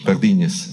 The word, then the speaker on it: Pardines: José Antonio Pardines o Pardines, non so esattamente come Pardines. si pronuncia. – Pardines.